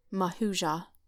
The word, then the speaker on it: Mahujah: In verse 2, "As I was journeying and stood upon the place, Mahujah," did I say that right, Zach? – Good one.